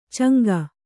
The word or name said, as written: ಚಂಗ